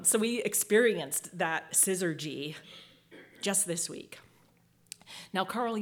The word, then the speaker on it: syzygy: Syzygy.